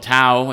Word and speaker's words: Tau: And you probably, if you've studied languages, know that the Greek alphabet came from the Phoenician, and they have a letter called Tau. That's how we pronounce it in English anyway, although probably in Greeks we'd pronounce it more like the Hebrew Tav.